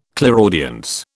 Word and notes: clairaudience: British English

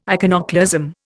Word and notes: iconoclasm: Indian

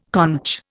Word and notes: conch: Indian

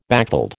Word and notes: backveld: American English

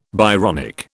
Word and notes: Byronic: British English

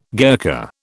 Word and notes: Gurkha: British English

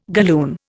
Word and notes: galloon: Indian

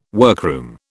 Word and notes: workroom: British English

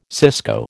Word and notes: cisco: American English

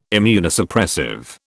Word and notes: Immunosuppressive: British English

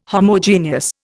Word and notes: Homogeneous: Indian

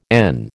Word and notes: n: American English

